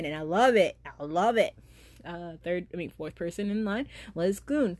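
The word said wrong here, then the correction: Gon: Gone. The first time I say his name, it's wrong. Gone.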